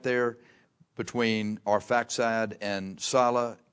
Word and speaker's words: Salah: Arphaxad begat, Genesis 10, 24, Arphaxad begat Salah, and Salah, it's pronounced, Salah begat Eber, and unto Eber were born two sons, okay, Peleg and Joktan.